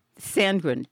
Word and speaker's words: Sandgren: Tenny Samgood? – Sandgren. T-E-N-N-Y-S. Tennis.